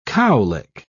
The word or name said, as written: cowlick